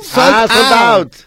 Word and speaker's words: sold out: Oh, según escuchaba, fue un éxito porque el 80% de los artistas que se presentaron tuvieron soldado. – ¿De verdad? – ¿Cómo soldado? ¿No se dice así, soldado? – Sold out.